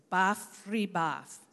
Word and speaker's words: bafribaf: It's bafribaf. So three syllables.